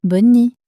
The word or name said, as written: bonne nuit